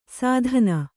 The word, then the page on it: ಸಾಧನ: kannada; sādhana